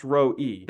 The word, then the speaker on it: Roi: So it's spelled El, which is the name for God, and then Roi, which would be pronounced Roi.